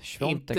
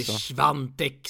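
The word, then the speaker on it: Świątek: Bra Alex, Sviontek, Inte Svantek som vissa säger.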